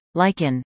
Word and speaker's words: lichen: R-H-Y-T-H-M. Question 2. The plant whose name is pronounced, lichen, is spelled this way. The answer? L-I-C-H-E-N.